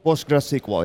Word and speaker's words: PostgreSQL: We've been learning all about PostgreSQL. And am I saying it right? PostgreSQL? – PostgreSQL, yes.